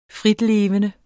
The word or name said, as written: fritlevende